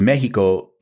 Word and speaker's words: Mexico: that X was placed there because Mexico is supposed to be pronounced Mexico. The X makes that SH sound.